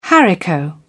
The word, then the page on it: haricot bean: British English